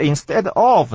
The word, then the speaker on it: instead of: 再来是 C 段，C 段的阿山说的第一句话啊，Well, since we're collecting gifts instead of red envelopes，这个 instead of 呢要连在一起念，我们念作 instead of。 Instead of 非常自然的 OF 的 F 就不发音了，因为后面呢是一个子音的 red 那个 R 的声音了。